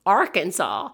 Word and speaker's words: Arkansas: In Arkansas, it is illegal to mispronounce Arkansas. The way Arkansas is intended to be pronounced is a French pronunciation of a word in the Sioux language,